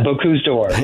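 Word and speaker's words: Bocuse d'Or: I mean, I guess that's why, you know, y'all were successful in luring Boku d'Or here, right? I think that's how you pronounce it. – Yeah. – Is that correct? – Yeah, Boku d'Or, yes.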